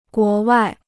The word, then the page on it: 国外: guó wài